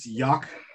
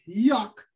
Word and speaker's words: Joc: So, it's Jock Pederson. – You know what, man? For me, it's Jock. All right. – It's pronounced Yuck.